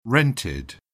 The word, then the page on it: rented: British English